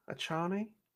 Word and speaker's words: Achane: A-C-H-A-N-E. Achane? Achane? Devon Achane? – I like Achane.